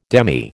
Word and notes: demy: American English

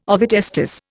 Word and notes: ovotestis: Indian

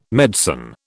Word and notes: medicine: British English

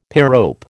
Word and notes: pyrope: American English

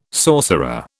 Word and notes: sorcerer: British English

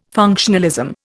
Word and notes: functionalism: Indian

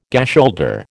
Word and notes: gasholder: American English

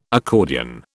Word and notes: accordion: British English

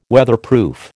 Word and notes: Weatherproof: American English

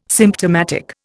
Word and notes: symptomatic: Indian

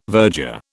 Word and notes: verger: British English

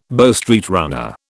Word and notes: Bow Street runner: British English

